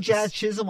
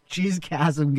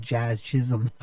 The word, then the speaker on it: Jazz Chisholm: And I like Jazz Chisholm a lot, and I love how people mispronounce his name. – It is funny. – Even my mom called me. She's all, who's this Jizz Chasm guy?